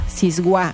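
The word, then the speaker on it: siwáj: Bueno, Siguá se escribe con la letra S.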